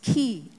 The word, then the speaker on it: chi: So here we have, remember the chiastic that I told you, it's letter, that's not X, but it's chi. It's a Greek alphabet.